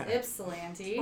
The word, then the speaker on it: Ypsilanti: So the Y is silent. – The Y is silent. So yes, I have been saying it wrong on purpose. It is Ypsilanti. – Or it's not silence, but it's an I instead of a Y.